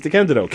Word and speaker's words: DeCandido: D-E, because whenever I say, hi, I'm Keith, my name is Keith DeCandido.